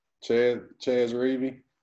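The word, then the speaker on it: Chez Reavie: Chez Reavy. How you say his name? – Chez Reavy? – Yep.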